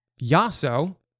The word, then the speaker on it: Yasso: I appreciate you coming on uh, because Yasso,